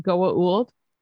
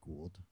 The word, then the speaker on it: Goa'uld: Did he pronounce it Goa'uld? – No, he called it he called it Gould.